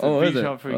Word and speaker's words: Bichon Frise: Is that just a Bichon Frise and you just put an A on the end of it? – That's actually how you pronounce it. Bichon Frise.